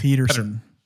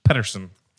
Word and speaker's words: Pettersson: I would have without a doubt Pettersson? Said Peterson. – Pettersson.